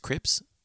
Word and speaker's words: CRPS: A bad accident, and now she suffers from CRPS, Crips, that's how you say it.